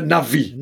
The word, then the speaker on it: Navi: Oh, you don't know how many people say Navy. – Well, they're all wrong. You just, that's just wrong. It's just, it's not like, oh, it's an opinion. It's like, no, it's just not how you pronounce it. Anyway. – It's the Navi.